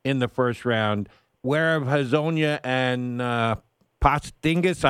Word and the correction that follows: Porzingis: I always mispronounce his name. Give me a hand here. – Porzingis. – Porzingis uh, how are they eh, eh, not being involved in the, the combine?